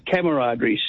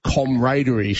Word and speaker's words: camaraderie: But I suppose really in English it should be comradery, shouldn't it? But we just don't say that.